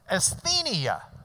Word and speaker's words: Asthenia: T-H-E. The V-looking shape, the new, is an N. And then E-I is just I-A in the way we pronounce it. Asthenia.